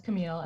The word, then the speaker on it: Camille: Um, but yeah, it's Camille, and the last name is Diaz.